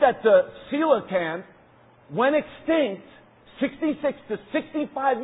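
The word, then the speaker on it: coelacanth: It's actually pronounced a coelacan. Say that with me. Coelacan. Coelacan. Scientists said that this fish went extinct, listen to this, 66 million years ago.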